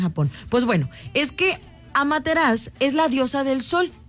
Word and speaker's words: Amaterasu: Se escribe Amaterasu, pero se pronuncia Amaterasu, porque la U no la pronuncian los japoneses.